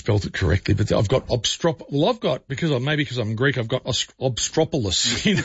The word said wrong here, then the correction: obstreperous: So that's what it is. It's obstreperous, and obstreperous.